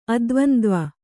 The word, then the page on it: ಅದ್ವಂದ್ವ: Kannada